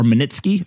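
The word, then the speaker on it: Magnitsky: But his lawyer's name was Sergei Maniskov, I think is how you say it. I may have it, or Manitsky, I think is actually how you say it.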